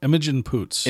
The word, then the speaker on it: Imogen Poots: Imogen Imogen Poots.